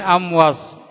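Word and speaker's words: Amwas (أموص): Alif mim waw sot. Amwas ya bacanya.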